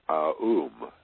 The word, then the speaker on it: AUM: both as a symbol visually and as a sound, pronounced AUM, A-U-M.